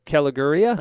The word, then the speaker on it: Caligiuri: here yet who has scored, but Wolfsburg up on, there we go, uh, Caligiuri. – Ah, Is that how you say it? I believe so.